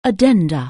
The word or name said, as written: addendum